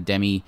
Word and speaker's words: Demme: The next one, or the last one I'll talk about, is a 1976 film directed by Jonathan Demme, or Demme, depending on your pronunciation, produced by Roger Corman.